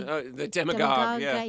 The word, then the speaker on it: Demagogue: How do I say it? Don't Let the Demigod Got You Down, or the The Demagogue. – Down. – Yeah, the Demagogue.